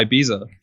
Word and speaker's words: Ibiza: Is that how I pronounce it? Am I saying it right? – What's it called? – Ibiza, Ibiza. – Oh, like the place? – Yeah. Am I pronouncing it right? Yeah, Ibiza.